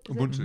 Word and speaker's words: Ubuntu: You know, Desmond Tutu, Bishop Desmond Tutu came here a few years ago, and he he used this concept of I mean, I'm not sure if I'm pronouncing this right Ubuntu. – Ubuntu, it? – yeah.